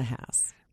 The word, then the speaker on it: Haas: Like the avocado. – Yes. Well, some people call them Haas avocados, too. Most people have no idea how to pronounce it. – That's true. – I just let them know, at least for my name, pain in the Haas.